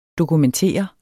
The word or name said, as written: dokumentere